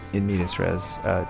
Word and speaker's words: In Medias Res: Is it In Medias Res? – It's Latin, so there is actually no wrong way to say it, because I think you're just supposed to say it phonetically.